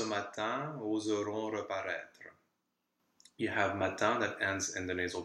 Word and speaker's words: matin: Matin here also has a silent n, so you could be tempted with this logic to do the liaison. But in this case, we don't, because we normally don't do a liaison after a noun in the singular, which mm-hmm.